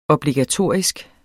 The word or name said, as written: obligatorisk